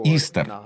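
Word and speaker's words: Easter: На английском языке имя произносится как «Истер».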